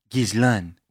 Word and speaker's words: Ghislaine: Well, we did pull up like a French pronunciation video yeah. and it was like Ghislaine.